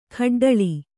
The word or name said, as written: ಗೊಡ್ಡುಳಿ